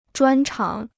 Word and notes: 专长: zhuān cháng

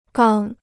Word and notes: 刚: gāng

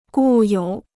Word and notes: 固有: gù yǒu

